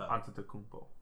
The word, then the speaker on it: Antetokounmpo: Is it a P or do they make a B sound at the end there? – Po. – Is it Po? – P. – I'm pretty sure it's Po. – Antetokounmpo.